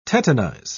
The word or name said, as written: tetanize